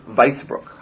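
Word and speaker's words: Weissbruk: And in evening time, as the afternoon wears on, you guys reach the town of Wissenbrook. Oh, sorry, Weissbrook. – How do you spell that? – Weiss is W-E-I-S-S, and then Brook is B-R-U-K. It's actually all just one word.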